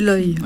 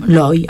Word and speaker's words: Loy: Ensuite, Olivier Appert présentera le personnage de Mina Loy, je ne sais pas comment on prononce. – C'est Loy. – Loy, hein, Mina Loy, la poétesse futuriste oubliée.